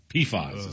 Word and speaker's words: PFAS: PFAS is how they pronounce it.